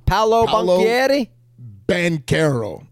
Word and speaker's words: Paolo Banchero: Off, Paolo Banchero.